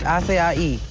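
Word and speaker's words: açaí: How do you say that berry drink, acai? – Berry acai. It's acai.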